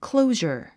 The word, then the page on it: closure: klóuʒər; クロウジャァ